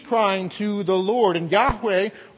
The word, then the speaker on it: Yahweh: L-O-R-D, all caps. And that is shorthand to represent the divine name of God. The divine name of God that is pronounced Yahweh or Old English Jehovah.